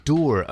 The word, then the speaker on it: dour: I was going to say dour affair, but I've been informed that it rhymes with tour. It's a dour affair.